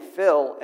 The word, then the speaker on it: Phil: You know, so many times I'll say my name, Phil, and many people will think I said Bill. They'll call me Bill. Why? Because they couldn't distinguish the PH sound as opposed to the B sound.